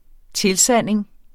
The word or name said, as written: tilsanding